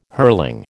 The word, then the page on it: hurling: American English